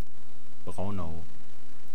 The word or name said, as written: Braunau